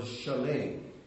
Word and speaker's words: Shalem: But the word, the way it's pronounced here in the text, is the word Shalem.